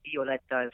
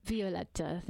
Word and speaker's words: Violetta: She's the, of course, my main character, and in the Italian language, the letter I is pronounced. So Violetta is how that's pronounced. – Violetta, there we go.